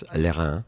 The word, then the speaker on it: Lérins: Let's call him Lérins. In French, it's Lérins.